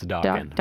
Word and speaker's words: Dokken: It's Dokken. Rhymes with rockin'.